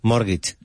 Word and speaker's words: mortgage: Now, the T is silent. – Mortgage.